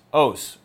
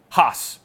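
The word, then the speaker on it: hos (ὅς): Quote, "The reading which, on the basis of external evidence and transcriptional probability, best explains the rise of the others is os, or as Erasmian Greek would pronounce it, hos.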